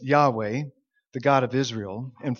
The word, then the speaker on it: Yahweh: we think it's pronounced something like Yahweh.